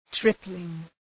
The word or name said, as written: tripling